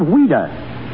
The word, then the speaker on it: Ouida: But why did she use the pen name of Ouida? – Because as a child, that was the way she pronounced Louise.